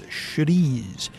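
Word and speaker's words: chouriço: It's spelled like chorico, but it's pronounced Churice.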